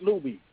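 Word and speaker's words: Luby's: So, no, I don't eat at Lubies. – And am I correct in pronunciation? Is it Lubies or Lubies? – Jew, you said it right the first time.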